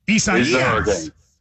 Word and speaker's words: Isaias: It's four syllables. The third one, you got to kind of draw that fourth syllable down like Louisville. It's Isaias. Isaias. – Isaias.